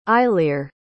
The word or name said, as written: eilir